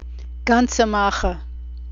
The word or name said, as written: gansehmacher